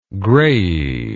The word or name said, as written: graiae